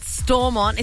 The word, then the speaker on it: Stormont: Second men's T20 international at Stormont. Is that how you say it? Stormont? – Yeah, go with that.